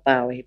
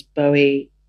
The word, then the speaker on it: Bowie: I did ask Tom beforehand because, you know, my generation, we said Bowie, and Paul Morley, who is the expert apparently on Bowie, says no, it's not Bowie, because Bowie rhymes with Zoe, which was his son's name. He then changed his name to Duncan. But I think Bowie rhymes better with Zowie.